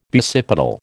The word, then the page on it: basipetal: American English